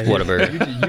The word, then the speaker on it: Whataburger: Those people are wrong. – They're wrong. It's Whataburger.